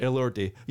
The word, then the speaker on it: Elordi: Elordi. Elordi.